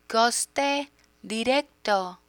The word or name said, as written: coste directo